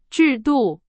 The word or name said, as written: 制度